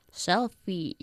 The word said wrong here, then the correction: shellfish: Shellfish. – Shellfish. – ¿Y egoísta? – Selfish. – Selfish, sin la H.